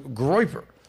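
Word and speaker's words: groyper: And then we'll also discuss what a term I'm quite frankly not familiar with, but I did my due diligence, the term groiper.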